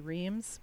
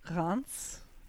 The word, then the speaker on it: Reims: Which, uh, you know, we like to say Reims because it's easier to say, but it's actually pronounced Reims.